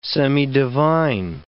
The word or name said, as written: semidivine